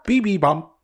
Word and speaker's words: bibimbap: And then he also ordered the, pardon me if I don't pronounce it properly, the bibimbap. – Bibimbap? – Bibimbap.